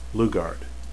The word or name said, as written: lugard